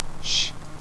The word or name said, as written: sh